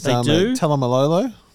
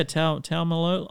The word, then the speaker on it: Taumalolo: Yeah, Taumalolo. I think that's wrong. I'm pretty sure he came out a year or two ago and said, "Oh no, you've been saying my name wrong the whole time."